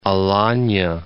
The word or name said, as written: Alania